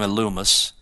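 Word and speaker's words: Elymas: So this Illumus, looks like a Limus, but in Greek you pronounce his name Illumus.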